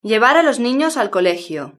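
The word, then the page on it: llevar a los ninos al colegio: European Spanish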